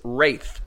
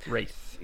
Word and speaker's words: Wraith: Wraith. – Ah, Wraith.